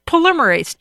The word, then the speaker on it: polymerase: Polyamorous chain reaction. – Yeah. – I think I mispronounced that. – Polymerase chain reaction.